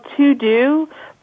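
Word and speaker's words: TeuxDeux: Another tool that I would highly recommend, and it's a really cool tool, it's something that's a little different, and it's called To Do, but it's spelled the French way, so it's T E U X D E U X.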